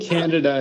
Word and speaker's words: Candida: And do you pronounce it, you said it, candida? Is that the right way? I've been saying it candida for 5 years. Like a idiot. – I think candida is the correct pronunciation.